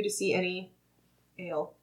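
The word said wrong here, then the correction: Aiel: other than Rand. – I eel. – I knew I was going to say it wrong, so I just kind of gave up. Say it again. – I eel. – I eel. That's confusing because it starts with an A.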